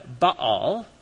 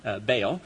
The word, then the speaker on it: Baal: In the lower right, you see the Canaanite god Baal, or in English we butcher it to Baal.